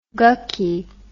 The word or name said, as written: gucky